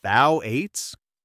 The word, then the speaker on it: Phthalates: I've heard people worry, am I even saying it right? Phthalates? Is that correct? – That's spot on, and the concern is understandable.